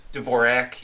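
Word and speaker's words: Dvořák: you did about Dvorak. Did I pronounce it right? – Dvorak, yeah. Dvorak. Close, close enough.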